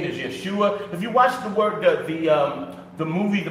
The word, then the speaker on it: Yeshua: And that's the name Yeshua. Some people say Yahushua, and that is incorrect.